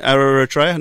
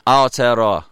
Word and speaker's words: Aotearoa: How do I say it? Aotearoa? No. – Aotearoa. – Aotearoa, yeah.